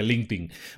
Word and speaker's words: LinkedIn: Se escribe LinkedIn, se pronuncia LinkedIn.